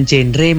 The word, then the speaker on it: Genre: Dan berkaitan berbicara mengenai genre, sebetulnya bukan genre maupun gender, tapi bukan, ya? – Genre seperti itu. – Okay. – Jadi dibacanya memang genre, generasi berencana.